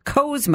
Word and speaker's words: Cowles: So two and a half years later, my friend Dusty invited me uh, to hike, and I have always called it Cowles Mountain. Most people I know around here call it Cowles Mountain, but apparently it's it's actually pronounced Coes Mountain.